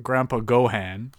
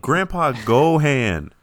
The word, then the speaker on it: Grandpa Gohan: it's pronounced Grandpa Gohan. – Grandpa Gohan.